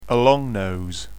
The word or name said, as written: a long nose